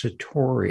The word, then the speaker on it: satori: Is that sakari? Am I butchering that? There's a word. – What you're thinking of is satori. – Satori, thank you.